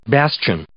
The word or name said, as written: bastion